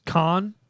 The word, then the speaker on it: Khan: I understand that this is Khan.